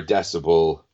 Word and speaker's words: Decibel: The new Decibel magazine came out, or Decibel, as it's properly pronounced.